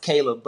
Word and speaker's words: Caleeb: And just so you know, my dad added an extra E in my name, so it's pronounced Caleb, but it's spelled Caleeb.